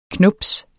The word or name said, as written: knubs